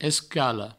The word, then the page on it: escala: escalo; provençal